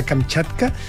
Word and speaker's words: Kamchatka: Esto fue lanzado desde el cosmódromo de Plestex hasta la península de Kamchatka. Esto es todo en el extremo oriental. – ¿Qué pronunciado? – ¿Kamchatka? – Sí, súper bien.